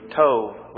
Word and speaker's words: Tob: So Jephthah fled from his brothers and lived in the land of Tob. Well, we can pronounce that according to the Hebrew, Tov, which means good.